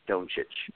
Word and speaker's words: Doncic: It is Doncic. With the long O sound, yeah.